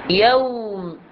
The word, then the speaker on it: يوم: يجب أن ننطق الياء في يوم بهذا الشكل، ياء، وثم هنا نأتي للواو ونعطيها حقها كما هي. يوم، تلفظ الواو أنه تكون تدوير الشفتين بشكل كامل مع التقدم مقدارا إلى الأمام. يوم. أما نقول يو، يوم، هنا قربنا الفتحة إلى الضمة وقربنا الواو إلى الألف، فتعادلت، يعني أصبح صوت واحد مثل صوت مدي، حرف مدي جديد.